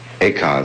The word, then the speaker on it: Eka: Eka is Japanese pronunciation, like Zen is the Japanese pronunciation of the Chinese Chan.